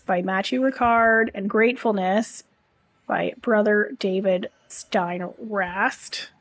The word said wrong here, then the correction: Steindl-Rast: I just did not pronounce his name properly. Those are really good. Matthew Ricard is a Buddhist monk. David Steindl-Rast is a Benedictine monk.